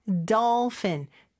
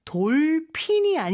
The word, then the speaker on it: dolphin: 그 다음에 dolphin. 돌핀이 아니죠? doll, 어 발음이 더 가깝고, F 발음으로 fin 해주셔야 돼요.